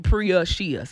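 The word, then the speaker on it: Preashea: So my name is Prashia, but it's easy to spell if you pronounce it Priya-Shia. So Priya-Shia. – It's P-R-E-A-S-H-E-A.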